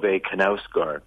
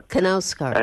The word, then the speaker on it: Knausgård: Knausgård? – Yeah, it's you actually pronounce the K. It's Karl Ove Knausgård. – Knausgård.